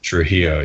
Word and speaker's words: Trujillo: Is it Rob Trujillo? I always get his last Trujillo. Name. Yeah. – Trujillo, yeah.